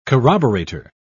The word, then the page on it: corroborator: British English